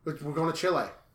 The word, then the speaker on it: Chile: You know, there's a town near, there's a city near Rochester that's called Chile. Spelled Chile. It's pronounced Chile.